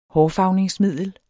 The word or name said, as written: hårfarvningsmiddel